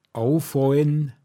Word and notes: Fåcknba(n)gaschd: Pinzgauer Mundart

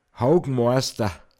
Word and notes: Håoghoiz: Pinzgauer Mundart

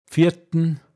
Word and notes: bfiatn: Pinzgauer Mundart

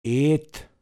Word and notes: zeaschd: Pinzgauer Mundart

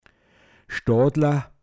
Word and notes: stådln: Pinzgauer Mundart

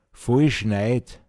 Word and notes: nit vakiifen kunna: Pinzgauer Mundart

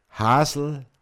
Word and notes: Håckbräid: Pinzgauer Mundart

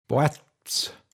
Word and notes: hanteg: Pinzgauer Mundart

